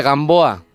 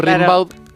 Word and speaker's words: Rimbaud: Gamboa. – Ahí va. – Claro, como se escribe, se pronuncia Gamboa, pero es Rimbaud.